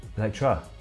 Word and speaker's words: Elektra: What spin-off did Jennifer Garner Electro. – No. – Oh, go on. – I mean, yeah, but you said it wrong. – Elektra. – Elektra, yes.